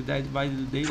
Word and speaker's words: Dead by Daylight: Uh, cara, e você joga Dead by Daylight. Falei certo o nome do jogo? Dead by Daylight, Falou.